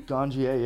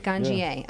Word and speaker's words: Ganjier: Ganjier, yeah. I'm a teacher. – Ganjier, yeah. Okay. Yeah. That's how you say it.